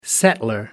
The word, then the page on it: settler: American English